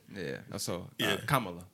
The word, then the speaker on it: Kamala: So it's comma, you know, like I'm trying to say apostrophe, but punctuation. So Kamala Harris.